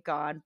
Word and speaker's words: Gahan: but the way the UK, you know, the English way is supposed to be Dave Gahan,